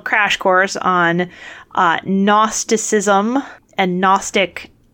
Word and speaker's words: Gnostic: That's a Gnostic gospel with a silent G.